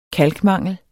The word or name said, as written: kalkmangel